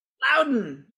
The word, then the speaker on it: Loudoun: called the Loudoun United. – There you go. – He got it. He nailed it. – Loudoun. – Oh, I said Loudoun. – Yeah, you put the accent on the Don.